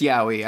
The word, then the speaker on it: yaoi: Yaoi? Yaoi? – I believe it's Yaoi. I believe you Yaoi. Say it like Sylvester the Cat Looney Tunes.